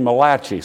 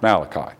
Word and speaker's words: Malachi: I had a professor that called him Malachi, said he was the only Italian writer in the Bible. I think it's pronounced Malachi.